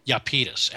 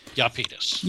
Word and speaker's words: Iapetus: The correct pronunciation, as far as we've been able to tell, is Iapetus, actually. – Iapetus.